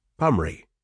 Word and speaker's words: Poundbury: With typical English disdain for the middle parts of names, Poundbury is pronounced Pumry.